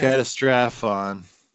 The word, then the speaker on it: Catastrophon: forgive me if I mispronounce this, this has got quite a few syllables in it, Catastrophon? – Yeah. – Catastrophon.